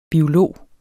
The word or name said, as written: biolog